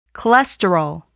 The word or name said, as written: cholesterol